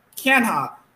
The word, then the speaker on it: Canha: You have Mark Canha, if I pronounced his name correctly.